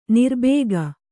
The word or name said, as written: ನೀರುಬೊಕ್ಕೆ